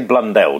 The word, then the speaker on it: Blundell: Let's say Blundell, because otherwise it sounds like Brundle, so it's easy to get Blundell and Brundle confused.